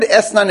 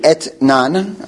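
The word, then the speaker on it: Etnan (אתנן): By the way, the word Esnan itself, you would pronounce it Etnan.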